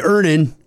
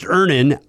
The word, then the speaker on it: Earnin: Earnin. – Earnin. – E-A-R-N-I-N is pronounced Ernin. I don't know how else you would pronounce it, but they really want it clear that that's how to pronounce it.